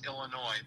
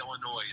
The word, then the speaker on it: Illinois: Well, I pronounce it without saying the S, Illinois. But a lot of people will come in and pronounce it Illinois.